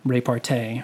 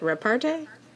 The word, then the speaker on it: repartee: She found it hilarious that I can't pronounce repartee. I still... – You are not doing it right either. – All right, well... – Nope. – Is it repartee? – There it is.